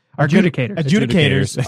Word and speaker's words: adjudicators: Adjunctators, right? Is this how it's pronounced? Adjunctators? – Adjudicators.